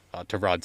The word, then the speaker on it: Tyrod: Tyrod Taylor, that's the way he pronounces it. I keep missing that.